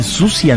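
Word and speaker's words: Siouxsie: O sea, ¿vos decís que los yankees dicen Susi and the Banshees? – O sea, no pronuncian esa X espectacular.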